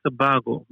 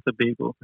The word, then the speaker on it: Tobago: There's some people who say Tobago, but it is really Trinidad and Tobago.